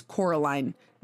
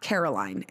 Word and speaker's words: Coraline: Because her name is Coraline, not Caroline.